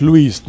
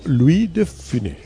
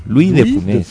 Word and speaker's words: Louis de Funès: No es Luis, no, Louis de Funès. – Bueno, Louis de Funès.